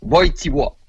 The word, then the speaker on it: Wojtyła: En fait, ça s'écrit Wojtyła, mais ça se prononce Wojtyła.